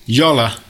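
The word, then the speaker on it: Jolla: So yeah, more mobile Linux news is that Yola, as I've now learned it's pronounced, have just released their...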